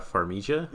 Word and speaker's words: Farmiga: It's Vera Farmiga.